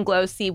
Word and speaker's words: Glōci: I want to talk about glowchi. Is that how you say it? – Glossy. – Glossy. – Yep. – Oh, close.